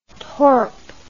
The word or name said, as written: torp